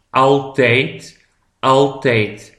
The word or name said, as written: altijd